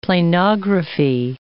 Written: planography

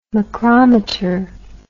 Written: macrometer

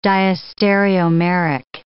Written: diastereomeric

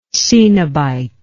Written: coenobitism

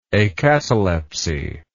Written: acataleptic

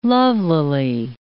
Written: lovelily

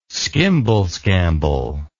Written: skimble scamble

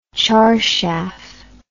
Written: charshaf

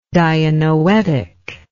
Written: dianoetic

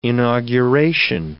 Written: inauguration

